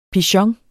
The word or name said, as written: pigeon